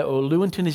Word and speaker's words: Lewontin: That's a point that's made very clear by a really famous American geneticist, actually, a man named Richard Lewontin, Lewontin is his name sometimes pronounced.